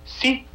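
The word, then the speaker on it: sí (四): Así como aquí, el número aquí cabalístico es el 13, dicen que el número 4, su pronunciación en mandarín es sí, lo que se parece mucho al sí, que significa también para ellos muerte.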